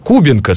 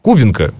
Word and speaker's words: Кубинка: Человек мне пишет, Кубинка, ты что, не играешь в World of Tanks? Кубинка, слушайте, Кубинка, что вы мне рассказываете? Это вы просто не знаете ударение правильно.